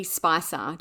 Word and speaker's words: Spicher: Just as kind of a note, her name is spelled S-P-I-C-H-E-R, and we have seen it pronounced a few different ways, but Spicer seems to be a fairly common one, so we'll go with that.